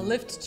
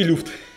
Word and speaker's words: Lyft: Э, а left через... А left пишется через Y, да. А, ага. У меня поэтому друзья, которые, мы с ними ездили в Америку, они не очень знают английский пока что. Вот, и они такие, вызови люфт.